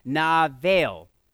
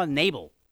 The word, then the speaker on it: Nabal: Navel. It's interesting. Hebrew is fascinating. I saw Nabal. And then I hit the little button for the pronunciation, and it said, Navel.